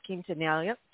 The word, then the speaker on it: Quintanilla: And one of them I want to mention is because I absolutely loved your role as Suzette Quintanilla. How you say it? I'm not Mexican. I can't say it. – No, you got it right.